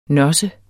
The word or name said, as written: nosse